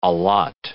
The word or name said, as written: allot